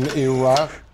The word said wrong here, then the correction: Iroquoian: Is it Iroquoian? How do you say it? Iroquoian.